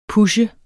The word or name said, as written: pushe